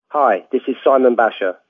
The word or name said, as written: Simon Basher